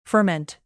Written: ferment

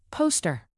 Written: poster

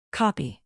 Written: copy

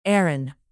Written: aaron

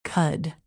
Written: cud

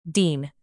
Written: dean

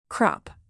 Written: crop

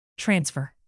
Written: transfer

